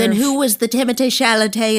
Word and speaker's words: Timothée Chalamet: I'm saying the name you're saying, Timothée Chalamet. Maybe you don't understand because it's the classical French pronunciation, but Timothée Chalamet.